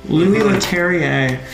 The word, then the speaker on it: Louis Leterrier: Louis Leterrier.